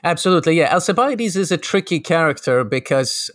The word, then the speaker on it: Alcibiades: I'm not quite sure how you pronounce it, but it's the student of Socrates, Alcibiades, that's how you pronounce it. Yes.